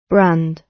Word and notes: brand: British English

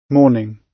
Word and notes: morning: British English

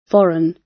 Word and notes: foreign: British English

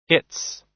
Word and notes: its: American English

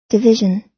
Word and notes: division: American English

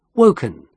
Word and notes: woken: British English

